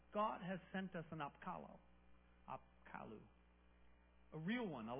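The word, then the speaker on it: Apkallu: They had a belief that their god would send messengers called Apkalu, Apkalu-ish, some spelling like that, I don't know. But it's Apkalu is how it's said.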